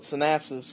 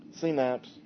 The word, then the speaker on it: Synapse: As I used to call it, Synapsis, which is probably not, I've been told I've been saying it wrong for years. But well, I didn't... – Synapse.